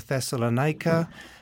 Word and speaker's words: Thessalonica: And then he arrives in Philippi, and then he goes to Thessalonica, as we pronounce it.